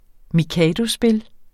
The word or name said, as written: mikadospil